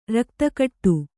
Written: ರಕ್ತಕಟ್ಟು

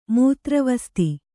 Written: ಮೂತ್ರವಸ್ತಿ

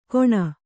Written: ಘೋಣ

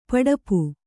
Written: ಪಿಡಿಪು

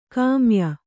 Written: ಗಮ್ಯ

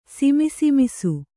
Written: ಸಿಮಿಸಿಮಿಸು

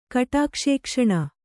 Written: ಕಟಾಕ್ಷೇಕ್ಷಣ

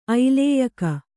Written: ಐಲೇಯಕ

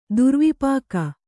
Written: ದುರ್ವಿಪಾಕ

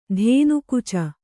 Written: ಧೇನುಕುಚ

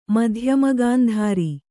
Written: ಮಧ್ಯಮಗಾಂಧಾರಿ

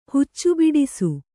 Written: ಹುಚ್ಚುಬಿಡಿಸು

